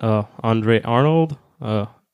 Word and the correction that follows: Andrea Arnold: uh, just say Andrea Arnold, and then it's about a teenage girl with nothing to lose joins a traveling magazine sales crew.